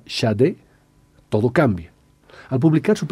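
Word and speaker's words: Sade: la pronunciación Shade por si había confusiones con un cierto marqués de, origen francés.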